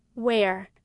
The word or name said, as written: wear